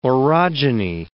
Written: orogeny

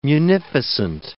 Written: munificent

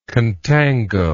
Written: contango

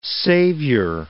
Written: saviour

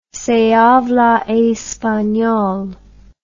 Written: se habla espanol

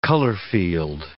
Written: color-field

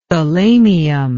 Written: thalamium

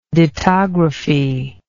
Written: dittography

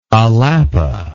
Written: alapa

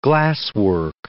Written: glasswork

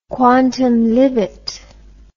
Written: quantum libet

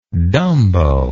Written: dumbo

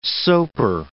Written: soaper

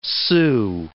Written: sue

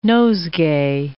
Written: nosegay

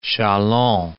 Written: Chalons

